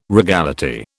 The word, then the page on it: regality: British English